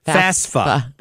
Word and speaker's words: FAFSA: FAFSA, I should say. Am I saying that right? FAFSA. That's it.